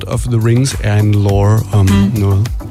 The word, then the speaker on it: lore: L-O-R-E.